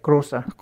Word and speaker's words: Rosa: udtaler det rigtige, Rosa, er det korrekt? – Ja, Rosa.